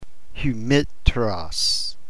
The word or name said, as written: hymeteros